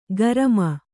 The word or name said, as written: ಗರಮ